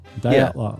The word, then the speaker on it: Dyatlov: Dyatlov. Yeah, for some reason, I always just... Dyatlov. I always... – Oh, that's a good one. I like that one. – A bit of a Russian... – Dyatlov.